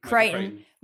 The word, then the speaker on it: Crichton: The writer was Michael, I don't even know how to pronounce Crichton. – It. Crichton.